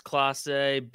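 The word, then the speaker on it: Clase: Is it Classe? Is it Kloss? – I'm reasonably certain it's Classe, but there hasn't exactly been games for me to hear it.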